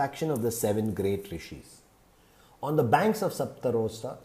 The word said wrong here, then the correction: Saptasrota: Sapta, let me pronounce this, Sapta, Saptasrota. On the banks of Saptasrota,